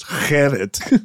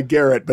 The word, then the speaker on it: Gerrit: I mean, because my first name is Garrett, but spelled the Dutch way, and in fact pronounced Gerrit. So good luck with that. You know, I mean, I go by Garrett, but if I ever go to like any Dutch family reunions, everyone, Gerrit, it's good to see you.